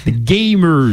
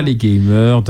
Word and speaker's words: gamers: Je note que tu l'as prononcé comme il le faut. Hein. Gamers ! Exactement. C'est pas les gamers, non. Gamers.